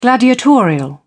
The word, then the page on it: gladiatorial: British English